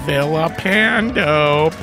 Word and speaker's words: Villapando: There's Vincent Villapando, pronounced with the L's, making an L sound, not a Y sound.